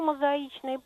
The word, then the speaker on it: мозаичный: А почему мозаичный пол, например, вот ударение на «и»?